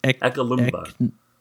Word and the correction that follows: Eknalobma: Ekna, you're forgetting an N in there.